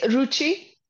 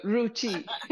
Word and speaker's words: Ruchi: It's Ruchi. Like I said, you know, the designer brand Gucci. So Ruchi.